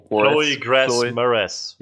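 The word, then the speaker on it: Chloë Grace Moretz: Mo Chloe Grass Moretz.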